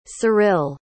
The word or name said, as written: ceril